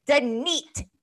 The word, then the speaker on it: Danit: This is what I do now of Down and Dirty with Danit. Because on my last episode, I said that and the comedian still said my name wrong. So I'm going to pronounce it Danit, D-A-N-I-T.